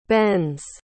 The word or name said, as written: bens